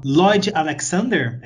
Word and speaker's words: Lloyd Alexander: Você sabe como pronunciar o nome do cara? É o Lloyd Alexander, é isso? – Isso mesmo mesmo, que é composto por cinco romances.